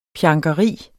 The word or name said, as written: pjankeri